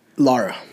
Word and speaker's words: Lara: Lara. – Lara. L A R A. Pronounced Lara.